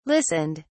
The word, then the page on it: listened: British English